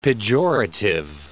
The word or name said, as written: pejorative